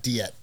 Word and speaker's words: Diette: How do you say your last name again? I've always said it wrong. – Diette. – Diette. Okay. I wasn't sure if it's Diette or Diette.